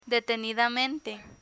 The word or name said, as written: detenidamente